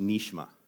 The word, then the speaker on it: nishma: And when you look at this word where it says never heard, it's actually a Hebrew word that's pronounced nishma. And nishma is this idea of an audible voice.